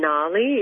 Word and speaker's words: Ngali: I really love the label Gnarly. It's spelled NGALI, and it's a Melbourne label.